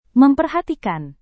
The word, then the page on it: memperhatikan: Indonesian